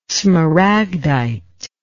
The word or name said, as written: smaragdite